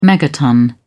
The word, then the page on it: megaton: British English